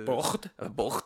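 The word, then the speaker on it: Börte: Bort c'est exactement comme ça que ça se prononce.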